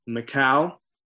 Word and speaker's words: Michal: So he gets home and he has, I'm sure, a very spirited conversation with his new wife, Michal. Remember, I decided to pronounce it that way. I think in the Hebrew, it's allowed.